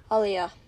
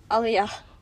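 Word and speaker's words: Olhão: It's spelt Olheo. How do you say it? – Olia. – Say it again. – Olia.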